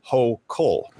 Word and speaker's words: Hochul: The newly inaugurated governor of New York, Kathy Hochul, I believe it's pronounced Hochul.